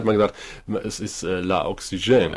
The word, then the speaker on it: Oxygen: Sauerstoff wird Oxygen ausgesprochen, mit Betonung auf der letzten Silbe.